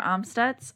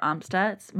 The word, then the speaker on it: Amstutz: And I'm Sandra Amstutz. All of my social media handles are at Sandra Amstutz.